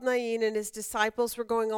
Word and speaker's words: Nain: That's why when I see it, I call it Nain, because that's how it's pronounced in most Middle Eastern cities.